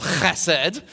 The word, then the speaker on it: chesed: which it looks like Hesed, but there's no H in the Hebrew alphabet, so it's this guttural chesed.